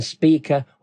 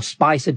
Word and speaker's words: Spica: And we're having that with Porrima and Spica, or Spicer, depending on how you want to pronounce it.